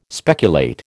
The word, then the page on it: speculate: American English